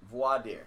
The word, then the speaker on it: voir dire: Is that how you pronounce it? – Voir dire. – Voir dire. Voir dire. Yeah. Excuse my French. Is that French? – Voir dire. – Voir dire. Yes.